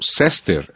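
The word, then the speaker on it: Cester: É o nome dele mesmo, no caso, Sester, se escreve com C, então é C-E-S-T-E-R, Sester. – Como se fosse Chester sem H.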